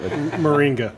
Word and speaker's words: Moringa: Um, it's like the Marlene. I'm going to butcher it if I say it. Moringa. Yeah, Moringa. There you go.